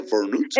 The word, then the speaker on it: burnout: Burnout.